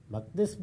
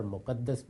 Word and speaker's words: مقدس: مقدس بھی اور مقدس بھی دونوں تلفظ صحیح ہیں.